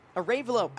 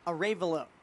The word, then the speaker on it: Arevalo: 9-1-2 to hit for Craig Conklin's squad. Angel Arevalo takes the first pitch up and away for ball one. Arevalo, I beg your pardon. Still trying to get that pronunciation right. Emphasis on the Ray. Angel Arevalo.